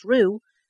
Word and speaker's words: Roux: Most of today's children with this name pronounced Roux will spell it Roux. So this is great on the radio, but so R-O-U-X is the name, but most of today's children are pronounced R-O-O. But they will spell it R-U-E, which is the female character in The Hunger Games, something I've never watched.